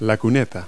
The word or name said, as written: curb